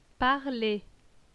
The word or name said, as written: parler